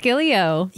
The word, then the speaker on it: Gileo: Alex Gileo. – Yes. – Not Galileo, but Gileo. Yeah. – Almost like Galileo.